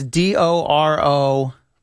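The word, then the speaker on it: Dorofeyev: Because it's pronounced Fayev. I believe it's Dorofeev.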